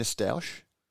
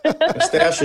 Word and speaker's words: stoush: Stoush is the correct way of pronouncing what you're trying to call stoush. – Well, I've said it the British way, so deal with it.